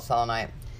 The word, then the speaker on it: selenite: So, one big thing was, and I know people pronounce this different ways, selenite or selenite. Some people pronounce it both ways. It's whatever you want to call it. I call it selenite.